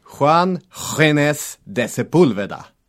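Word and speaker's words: Juan Ginés de Sepúlveda: Får jag uttala namnet? – Ja, varsågod. – Juan Genes de Sepulveda. – De Sepulveda? – Ja. Tackar vi tackar språkkonsult Kristina Mullio för det.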